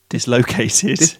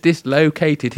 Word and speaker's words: dislocated: No, but when I was there, he was supposed to be the drummer, and he discolated his... That's not how you say it. Discolated? – Dislocated. – Dislocated his shoulder.